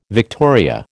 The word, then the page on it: victoria: American English